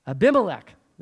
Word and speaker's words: Abimelech: King Abimelech, if I'm pronouncing his name right, forgive me. Abimelech. There, I got it right that time.